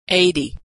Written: 80

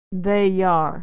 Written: they are

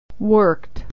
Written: worked

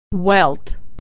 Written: welt

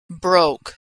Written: broke

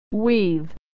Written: we've

